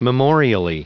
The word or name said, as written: memorially